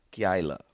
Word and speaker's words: Keilah: Keilah is how you pronounce it.